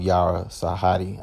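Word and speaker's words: Yara Shahidi: I love Chloe too, but I love Halle and I love Yara Sahadi. I think that's actually accurately how you say her name.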